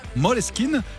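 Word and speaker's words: Måneskin: Moleskine, ça s'écrit Maneskin, mais ça se prononce en danois Moleskine.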